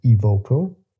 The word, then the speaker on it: Evoco: But before jumping deeper into the activities of your company, which is called Evoco, I hope I said it right.